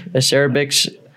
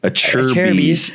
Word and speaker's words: Acerbis: Tusk back brake, acerbix. – Acerbis.